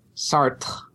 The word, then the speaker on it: Sartre: Sartre.